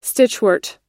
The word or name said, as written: stitchwort